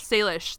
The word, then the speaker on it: Salish: Salish.